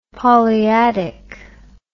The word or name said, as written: polyadic